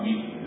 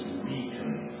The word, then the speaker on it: Wheaton: It's not Wheaton, it's Wheaton. It's the way they say it there.